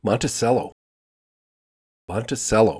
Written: Monticello

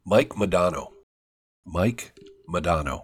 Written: Mike Modano

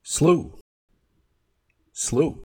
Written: slough

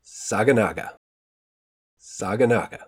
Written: Saganaga

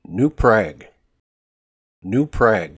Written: New Prague

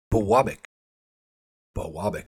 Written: Biwabik

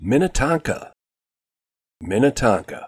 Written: Minnetonka

